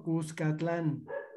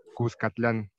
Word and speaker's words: Cuscatlán: Cuscatlán. – Cuscatlán.